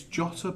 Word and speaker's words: Jota: It's Jota, by the way. – Jota. – Speaking to linguistics expert Mikey Burrows. – Oh, Mikey boy. – He's obviously a commentator for Wolves. All Portuguese use a hard J.